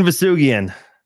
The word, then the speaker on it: Vousoughian: Farzin, soft G, hard G, Vousoughian. How we doing this? – Farzin, Vousoughian. – Nice. – Farzin, Vousoughian. All right. Hard G and Farzin.